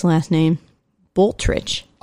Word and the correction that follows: Blutrich: I would like to believe that it's Blutrich. – Blutrich. – Just like really That's, German like that.